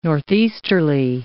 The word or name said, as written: northeasterly